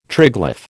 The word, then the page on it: triglyph: American English